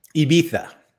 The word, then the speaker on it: Ibiza: You said Ibiza, like Ibiza? – Correct. – Okay. – Ibiza. I think that's actually how they say it there.